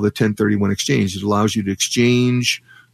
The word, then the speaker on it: 1031 exchange: And I hope, is it 1031 exchange? Did I say that right? – You did. Okay. 1031 exchange